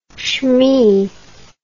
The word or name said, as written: schmee